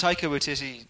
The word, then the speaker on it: Taika Waititi: look at Taika Waititi.